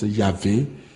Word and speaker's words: Yahvé: Et Dieu va donner le tétragramme sacré, Y, H, W, H, où on a ajouté les voyelles et on prononce Yahvé, ou encore Je suis, ou encore Adonai, ou encore Éternel.